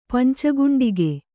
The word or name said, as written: ಪಂಚಗುಂಡಿಗೆ